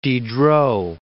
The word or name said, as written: Diderot